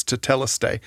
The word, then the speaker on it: tetelestai: and it's tetelestai.